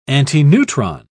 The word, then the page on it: antineutron: British English